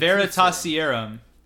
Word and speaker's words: Veritaserum: It's the Veritaserum.